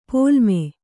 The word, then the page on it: ಪೊಲ್ಲಮೆ: pollame